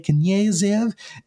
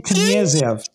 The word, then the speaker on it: Knyazev: He also played Anatoly Knyazev in 36 episodes of Arrow. I did not pronounce that right at all. – Please do that again. – Anatoly Knyazev. Knyazev.